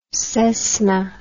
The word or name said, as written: Cessna